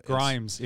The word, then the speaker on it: Grimes: Grimes. It rhymes with Rhymes.